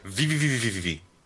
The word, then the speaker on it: VVVVVV: VVVVVVVV. – I didn't know you pronounced the full V. I thought you just went V. – No, it's V, V, V, V, V, V, V.